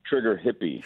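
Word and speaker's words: Trigger Hippy: also, a Trigger Happy. – Trigger Hippie. – Trigger Hippie. – H-I-P-P-Y.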